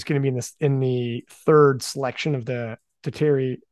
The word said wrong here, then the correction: tertiary: how do you pronounce that? – Tertiary.